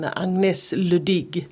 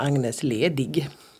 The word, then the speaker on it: Agnès Ledig: Och till en författare som vi precis har konstaterat uttalar sitt namn Agnès Ledig. Eller som vi säger här, Agnès Ledig.